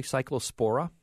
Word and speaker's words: Cyclospora: Am I pronouncing it correctly? Cyclospora? – That is correct, yes.